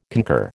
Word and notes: concur: American English

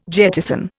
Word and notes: jettison: Indian

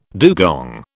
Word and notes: dugong: British English

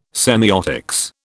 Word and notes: semiotics: British English

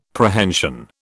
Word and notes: Prehension: British English

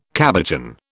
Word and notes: cabochon: British English

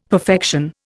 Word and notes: perfection: Indian

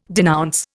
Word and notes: denounce: Indian

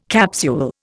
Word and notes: capsule: Indian

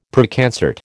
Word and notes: Preconcert: American English

